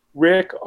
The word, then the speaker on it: Rik: And my name is spelled Rick, R-I-K, no C in that, Danielson, D-A-N-I-E-L-S-E-N.